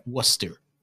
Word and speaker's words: Worcester: In weird local Boston accents, like how what is clearly Worcester is like Worcester. – Yeah, it's Worcester.